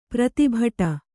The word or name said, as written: ಪ್ರೀತಿಬಡು